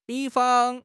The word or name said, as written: 比方